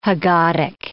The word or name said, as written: haggadic